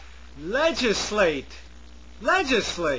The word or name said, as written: legislate